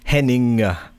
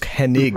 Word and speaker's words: Hennig: I try really hard to pronounce that, by the way, because all my life I've said Kurt Hennig, like I-N-G, and it's Hennig.